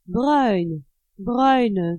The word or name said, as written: bruine